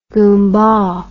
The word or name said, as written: gumbah